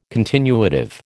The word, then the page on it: continuative: American English